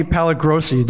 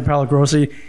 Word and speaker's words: Pallagrosi: And I'm here with Tony Pallagrosi. Did I pronounce that right? – And you it's did, very, very Italian. – Uh, the amazing Tony Pallagrosi.